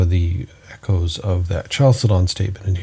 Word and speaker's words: Chalcedon: So we've read the definition of chalcedon, the CH is pronounced as a hard K, chalcedon.